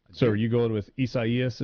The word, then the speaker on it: Isaias: Somebody said that it's Caleb in Starkville says that this is pronounced Isaias. The Spanish and Portuguese pronunciation for Isaiah. I guess if I think back to my my Spanish classes from years ago, and that's probably correct pronunciation.